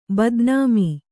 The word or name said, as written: ಬದನಾಮಿ